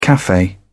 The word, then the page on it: cafe: British English